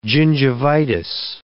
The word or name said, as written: gingivitis